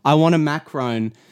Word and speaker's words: macaron: how are they pronounced? Macaroon. Macaroon. Macarum. – Macaron.